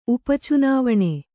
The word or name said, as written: ಉಪಚುನಾವಣೆ